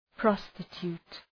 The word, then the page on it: prostitute: ‘prɒstı,tu:t